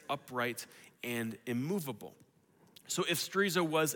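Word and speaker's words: sterizo (στηρίζω): Now, that's how we say it in English, but in the Greek text here, it's the word sterizo. It comes from that word, kind of like chorizo, but it has nothing to do with burritos. It just rhymes with it.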